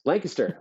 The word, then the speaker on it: Lancaster: You pronounced it correctly. – Yes, Lancaster.